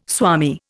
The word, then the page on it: swami: Indian